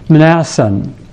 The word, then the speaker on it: Mnason: Finally, we are told that the team traveled to their destination and stayed with a disciple named Mnason. It's hard for us to pronounce that word, starting with MN, but it comes from the same root as the word mnemonic.